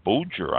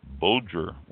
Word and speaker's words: Boeger: I next went to found a winery in Placerville, Boger, I believe it's pronounced, B-O-E-G-E-R, Boger Winery.